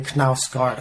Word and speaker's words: Knausgaard: It's interesting to me because, recently I've been working my way through this long autobiographical novel by this Norwegian writer, Carl Ove Knausgaard. I think that's the right way to pronounce it.